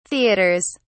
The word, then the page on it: theaters: British English